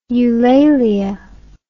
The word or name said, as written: eulalia